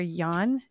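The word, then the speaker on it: Yuan: But the son is Christopher Yan. Is that how you say it? U-A-N.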